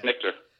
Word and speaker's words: Snitker: Is it Snitker? What's the Braves? – Yeah, Snitker.